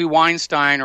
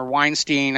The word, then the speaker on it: Weinstein: But there's a real interesting question about what's going on with Harvey Weinstein, or Weinstein. I've heard it pronounced both ways now.